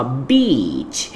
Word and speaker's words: beach: Se trocar a pronúncia dela minimamente, pode virar um palavrão bem ofensivo. Então, toma cuidado, exagera no som de I, assim, ó, beach.